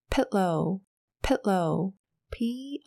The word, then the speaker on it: pillow: 戴着 eye mask，头当然也要靠着一个 pillow，pillow，P I L L O W，pillow。这边要请你注意一下的地方呢，是这个 I 的发音哦，所以呢，它是一个短音的诶诶诶，所以发起来是 pillow，pillow，pillow，枕头。